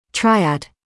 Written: triad

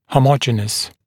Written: homogeneous/homogenous

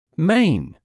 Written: main

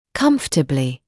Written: comfortably

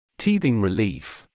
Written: teething relief

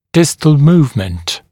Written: distal movement

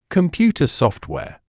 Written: computer software

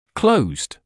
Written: closed

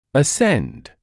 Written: ascend

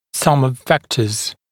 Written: sum of vectors

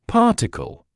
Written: particle

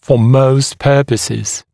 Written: for most purposes

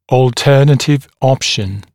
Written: alternative option

